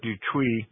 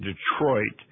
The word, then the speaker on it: Detroit: And then when we got up to Fort Detuit, actually, most Americans pronounce that differently. They pronounce it Detroit.